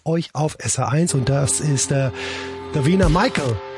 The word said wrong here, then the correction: Michelle: Davina Michelle, Entschuldigung, so spricht man sie aus.